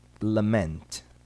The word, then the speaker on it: lament: Há, porém, duas palavras cuja sílaba tônica recai no sufixo ment. Ouça e repita. Cement. Lament.